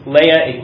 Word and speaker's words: Leah: By the way, I know it's pronounced Leah, but I've said Leah for so long that I can't get it out of my head.